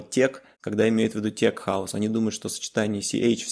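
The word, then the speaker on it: tech house: поэтому когда говорят тех-хаус вместо тек-хаус, это окей, но течь – это просто, просто ужас.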